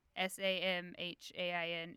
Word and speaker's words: Samhain: It's spelled Samhain. Have you seen that? – No. – Samhain. It's the witch way of saying it.